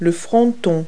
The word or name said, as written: pediment